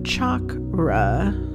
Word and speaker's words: chakra: like chocolate, not shock. Oh, I can't even say it. And then if you really want to get the correct Sanskrit pronunciation, it's chakra, and you're supposed to roll your R, and I am not very good at that.